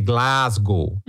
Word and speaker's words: Glasgow: Parabéns pela pronúncia correta de Glasgow. – ah, todo mundo falou Glasgow hoje. Ninguém falou Glasgow.